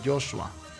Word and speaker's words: Josué: En hebreo se pronuncia Joshua, Josué, Joshua, que es el mismo significado de Jesús en el Nuevo Testamento.